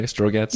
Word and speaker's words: Strogatz: Did I pronounce that right, by the way, Strogatz? – Yeah.